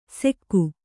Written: ಸೆಕ್ಕು

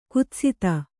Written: ಕುತ್ಸಿತೆ

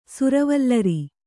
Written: ಸುರವಲ್ಲರಿ

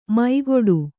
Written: ಮೈಗೊಡು

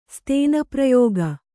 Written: ಸ್ತೇನಪ್ರಯೋಗ

